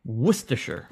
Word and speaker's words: Worcestershire: Worcestershire has got to be the word that eliminates most letters in the pronunciation. It's got to be. – Yeah. – Yeah. – Yeah. – Worcestershire.